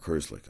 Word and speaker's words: Herzlich: Is that is that how you say it? – Mark? Yeah, I don't think that's right. Mark. Mark Herzlich, I'm sorry.